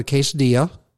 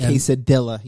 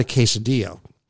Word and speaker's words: quesadilla: I had a quesadilla. – A quesadilla. You pronounced that wrong. Sorry. – Okay. I had a quesadilla, and it was delicious, and the food was very good, and the portions seemed to be very good as well.